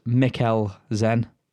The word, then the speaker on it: Mikkelsen: Yeah, and Mikkelsen is Mikkel, as you would pronounce the word nickel. Sen is pronounced Zen. So it's Mass. Mass Mikkel Zen.